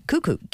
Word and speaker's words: kuku: Cuckoo, K-U-K-U. – I see, it's not a sort of posh pronunciation of couscous.